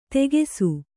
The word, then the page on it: ತೆಗೆಸು: tegesu